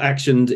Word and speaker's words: Axioned: more about you and Axioned well Axioned is yeah action spelt with an x so it's a x i O N E D.